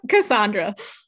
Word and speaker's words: Cassandra: Is it Cassandra or Cassandra? – Cassandra. – Cassandra. Okay. That's what I gravitate toward, but I wanted to be sure.